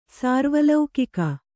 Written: ಸಾರ್ವಲೌಕಿಕ

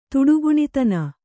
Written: ತುಡುಗುಣಿದನ

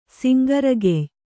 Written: ಸಿಂಗರಗೆಯ್

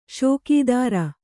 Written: ಷೋಕೀದಾರ

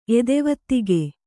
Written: ಎದೆವತ್ತಿಗೆ

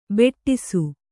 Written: ಬಡಿಸ

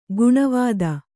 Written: ಗಿಣಿವೋದು

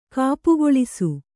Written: ಕಾಪುಗೊಳಿಸು

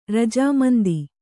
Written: ರಜಾಮಂದಿ

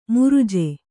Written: ಮೃಜೆ

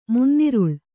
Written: ಮುನ್ನಿರುಳ್

